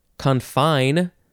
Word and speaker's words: confine: Second form is confine. Gotta emphasize the second syllable.